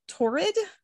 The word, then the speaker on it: Torrid: Torrid. It's like horrid, but with a T.